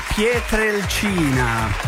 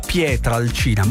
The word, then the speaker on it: Pietrelcina: Nel calendario dei mattinieri è detto San Pio da Pietrelcina. Per molti ha detto Pietrelcina, compreso Giuseppe Davelli, regia, e ha detto bene perché si dice pietre e non pietra.